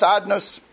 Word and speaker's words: Adnus: So even though it's spelled Yud Kei Vav Kei, but it's pronounced Adnus, so we're not allowed to pronounce the name of HaKadosh Baruch Hu as it's written.